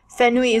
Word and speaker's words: Fenui: I'm excited to learn more about yourself and Fenui. Is that how we pronounce it, Fenui? – It's pronounced Fenu. – Fenu. – So new, as if fair and new, like something new.